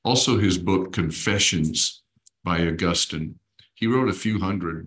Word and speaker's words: Augustine: Augustine, some people say Augustine, depending on where you put the emphasis on which syllable.